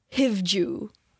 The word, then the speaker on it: Hivju: Hivju. I think it's Hivju, maybe it's not. H-I-V-J-U. Hivju. Hivju. – That sounds, sounds right to me.